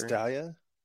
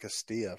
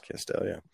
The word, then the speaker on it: Castalia: It's Castalia? I've been saying that Castilla for my entire life. I did not know that. Oh, I pronounced that wrong. – Yeah. – Sorry, guys. – Castalia.